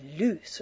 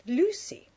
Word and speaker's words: Luce: That's spelt L-U-C-E, but not pronounced loose, of course. It's pronounced Lucy, as in juicy.